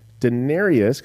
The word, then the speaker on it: Daenerys: So Daenerys, because I said that wrong, that's the queen of the dragon.